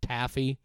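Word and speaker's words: Taaffe: I believe her name is pronounced Taffy, TAAFEE.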